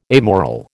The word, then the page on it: amoral: American English